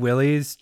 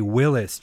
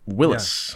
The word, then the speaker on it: Willys: I've been pronouncing Willys Jeep wrong this whole time. It's actually Willys Jeep.